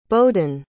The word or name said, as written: bohdan